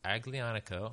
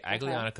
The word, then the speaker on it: Aglianico: Aglianico. – Okay, Aglianico.